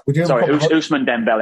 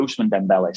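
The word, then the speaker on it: Ousmane Dembélé: Ousmane Dembele. I got the name wrong there. Ousmane Dembele. Sorry.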